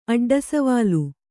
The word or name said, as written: ಅಡ್ಡಸವಾಲು